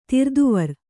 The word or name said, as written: ತಿರ್ದುವರ್